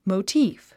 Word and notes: motif: moutíːf; モゥチーフ

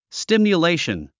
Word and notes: stimulation: stìmjuléiʃən; スティミレイション

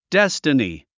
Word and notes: destiny: déstəni; デステニィ

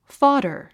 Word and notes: fodder: fɑ’də r; ファダァ

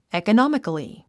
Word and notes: economically: ìːkənάmikli; イーコノミカリィ

